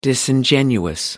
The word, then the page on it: disingenuous: American English